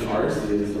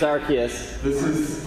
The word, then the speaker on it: Arceus: The alternate pronunciation Arceus over there. – Let me tell you, it's Arceus.